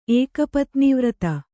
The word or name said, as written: ಏಕಪತ್ನೀವ್ರತ